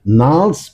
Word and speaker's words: Gnarls: Das G spricht man nicht. Gnarls Barclay mit Crazy.